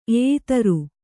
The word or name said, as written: ಎಯಿತರು